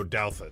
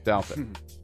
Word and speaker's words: Douthat: And in the New York Times, I never know how to pronounce his name, Douthat? – Oh, Douthat. – Douthat.